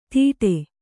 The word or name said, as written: ದೀಡೆ